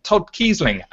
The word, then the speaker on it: Todd Keisling: And today we are joined by a wonderful writer who I've had the pleasure of working with, which is absolutely brilliant, called Todd Keisling, and I'm hoping I pronounced your name right that time.